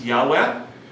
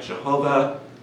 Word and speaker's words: Yahweh: It's Yud Hey Vav Hey, which sometimes gets pronounced as Yahweh. So this is the Yud Hey Vav Hey is kind of the, in a way, the unpronounceable name of God. People have tried to interpret it different ways, like Jehovah is definitely a mispronunciation.